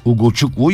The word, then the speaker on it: Ugochukwu: Ugochukwu yazıldığı gibi okunur abi yazmışlar.